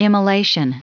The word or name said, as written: immolation